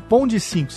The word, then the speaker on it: Pond5: Pond 5, se escreve.